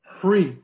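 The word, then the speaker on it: Hri (ह्रीः): Now that's a Sanskrit letter, which, you know, but it's spelled H-R-I-H, which our teachers have said you can visualize it, you know, as H-R-I-H, but it's actually a Sanskrit syllable that's above the crown of our head and universal living beings, Hri, okay, which transforms into the protector, my root guru Chinrezig.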